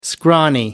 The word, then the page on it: scrawny: American English